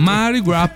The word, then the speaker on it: Mardi Gras: Mardi Gras Party, visto che te mi dicevi da non pronunciare mai la S. – No, assolutamente, mai.